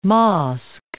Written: mosque

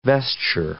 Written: vesture

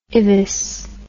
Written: IVUS

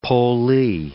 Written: pollee

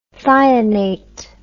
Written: thionate

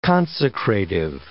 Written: consecrative

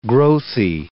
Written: growthy

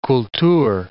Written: kultur

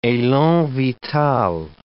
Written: elan vital

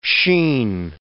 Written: sheen

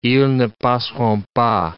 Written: ils ne passeront pas

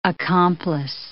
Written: accomplice

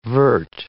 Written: vert

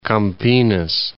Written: Campinas

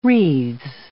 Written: wreaths

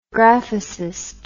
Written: graphicist

